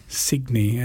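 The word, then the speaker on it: Cygni: The track was called SS Signy, spelt with a C. – Signy. – Signy, yeah. – Yeah. As sure. In signet.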